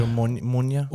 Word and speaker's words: Omonia: Omonia.